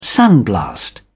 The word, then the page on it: sandblast: British English